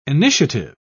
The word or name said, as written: initiative